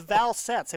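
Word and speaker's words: Valsetz: So it's Valsetts.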